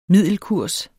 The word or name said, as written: middelkurs